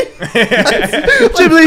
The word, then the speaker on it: Ghibli: Ghibli.